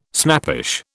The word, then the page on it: snappish: British English